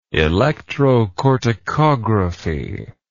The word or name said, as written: electrocorticography